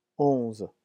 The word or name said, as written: onze